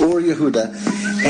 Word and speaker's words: Or Yehuda: Did I say that right? – Yes. – Or Yehuda.